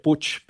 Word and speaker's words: Puig: Bueno, en catalán se pronunciaría Puig, pero en castellano en Argentina lo llamamos Manuel Puig,